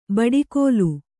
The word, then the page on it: ಬಡಿಗೋಲು: baḍi gōlu